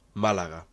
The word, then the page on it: Málaga: Spanish